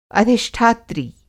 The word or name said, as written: अधिष्ठात्री